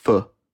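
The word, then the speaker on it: Phở: Die Deutschen würden ja aussprechen, Foga. – Mhm. – Und Vietnamesen sagen, glaube ich, eher Pho.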